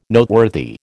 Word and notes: Noteworthy: American English

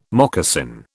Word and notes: moccasin: British English

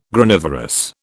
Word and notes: Granivorous: British English

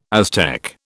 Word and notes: Aztec: British English

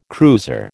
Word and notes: cruiser: American English

